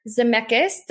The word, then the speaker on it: Zemeckis: A live action Pinocchio starring Tom Hanks, directed by Robert Zemeckis.